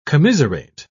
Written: commiserate

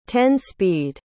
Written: ten-speed

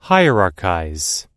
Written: hierarchize

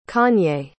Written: kanye

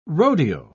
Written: rodeo

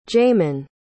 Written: jaymin

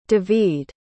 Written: devide

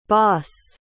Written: baas

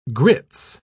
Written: grits

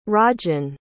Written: rajan

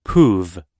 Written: poove

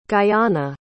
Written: guyana

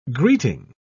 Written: greeting

